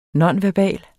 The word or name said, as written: nonverbal